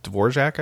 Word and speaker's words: Dvorak: There's one called Dvorak, I think. I think that's how it's pronounced, like the composer.